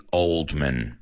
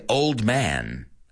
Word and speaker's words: Oldman: Now, the first one is probably one of the greatest actors ever, Gary Oldman. Now, no se dice old man, aunque es old man, es Oldman, min.